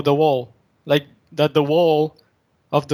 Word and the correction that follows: whole: Yeah, W-H-O-L-E. – Yeah, that's pronounced whole, not wall.